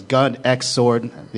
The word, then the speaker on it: Gun X Sword: But if you ever get a chance, Gun X Sword, the X is silent.